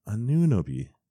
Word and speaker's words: Anunoby: Miles Plumlee, shout out on MLK Day with our boy OG Anunoby. That's how you pronounce his name. Anunoby.